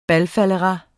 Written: BALFALDERAS

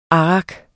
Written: arrak

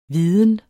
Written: viden

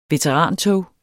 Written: veterantog

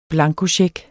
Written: blankocheck

